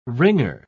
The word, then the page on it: ringer: British English